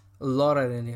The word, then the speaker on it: Laura Linney: So now, so the the other actors that were in the cast include Emilia Clarke, Viola Davis, Lola Lenny, Laura Linney. I mispronounced her name.